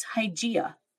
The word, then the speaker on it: Hygieia: It's Hygieia, you know, as Ooh. In hygiene. The Greek goddess Hygieia is the goddess of health. – Mm-hmm. – So, yeah, that's who you call in for health. And it's spelled very similarly to hygiene.